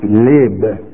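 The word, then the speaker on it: lib (לב): The word heart is spelled in the Hebrew L-E-B, but it's pronounced lib in that tongue.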